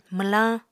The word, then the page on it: mwlañ: Apatani